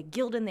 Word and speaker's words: Guldin: But in your defense, that it can be pronounced multiple ways. People say Guldin. They add, you know, different vowel sounds. They say Guldin. They say Guldan. It's just Guldin.